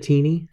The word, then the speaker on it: Tini: And that author, I just noticed, I think you pronounce it Tini, T-I-N-I, Howard.